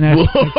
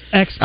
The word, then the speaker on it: espresso: There's no X. Expresso.